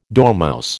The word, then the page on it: dormouse: American English